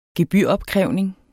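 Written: gebyropkrævning

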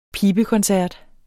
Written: PIBEKONCERT

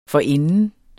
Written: forinden

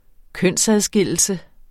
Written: kønsadskillelse

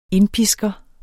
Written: indpisker

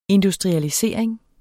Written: industrialisering

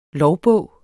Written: lovbog

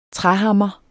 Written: træhammer

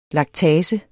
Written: laktase

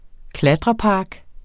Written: klatrepark

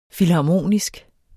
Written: filharmonisk

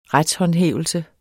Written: retshåndhævelse

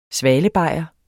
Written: svalebajer

